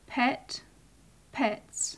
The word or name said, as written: pet-pets